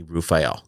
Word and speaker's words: Raphael: And so I think it was the archangel, but he pronounced his name to me, Raphael.